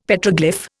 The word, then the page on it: Petroglyph: Indian